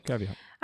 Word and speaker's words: caveat: Did I say that correctly? – Yeah, caveat. – I have to give that disclaimer because she changed the word. – I gave up. – Straight up gave up. 2 seconds in. – No, because then I'll listen back to this and be like, what did you say? – You said it right. Caveat. Yeah.